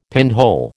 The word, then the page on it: pinhole: American English